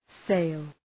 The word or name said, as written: sale